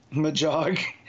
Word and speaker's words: Magog: So I'll say Magog. – Magog. – No. – It's a hard G.